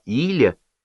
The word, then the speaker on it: Иля: 3-й сынок, все звали его не Илья, а Иля, с ударением на первый слог, молчаливый, застенчивый, но видно, что умненький.